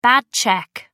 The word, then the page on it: bad cheque: American English